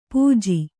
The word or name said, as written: ಪಾಜೆ